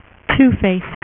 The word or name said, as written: Two-Face